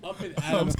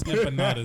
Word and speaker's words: empanadas: Nigga said up in Adams empanadas. Get the fuck out of here, bro.